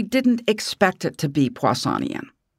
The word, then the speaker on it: Poissonian: And this is one of those communication issues that the scientists sort of assume you understand what Poissonian random distribution about a rate means. – I can't even pronounce it. What is it again? say it again. – Like Poisson is a Poissonian. – Oh, Poissonian. Yeah, right.